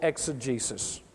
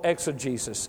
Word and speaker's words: exegesis: And if you want to go deeper with God, it is not sound biblical exegesis. I'll spell that for you. It is not sound biblical exegesis. E-X-E-G-E-S-I-S.